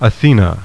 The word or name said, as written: ATHENA